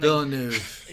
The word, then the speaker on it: Villeneuve: Dune is Academy Award nominee Denis Villeneuve's visually stunning. – Villeneuve.